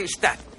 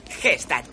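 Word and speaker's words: Gstaad: En Stad. G-Stad. Quitar la G es Has dicho que se pronuncia Stad.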